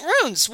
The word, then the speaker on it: ruin: Diphthongs are when you have a word that has two distinct vowel sounds together. So, poem, museum, ruin. Those are, I guess, supposed to be pronounced as two-syllable words.